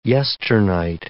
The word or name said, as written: yesternight